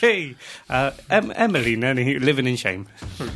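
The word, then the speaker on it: Emily Nenni: I'm going to say her name is Emily Nenny. – Emily Nenny. – Sounds all right. – Emily Nenny. That's it, yeah.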